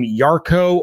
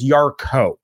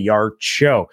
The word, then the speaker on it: Yarcho: James, are we pronouncing your last name Yarko or Yarsho? It is Yarko. So yeah, Yarko, easy breezy. But again, you're not going to hurt my feelings if you say Yarsho.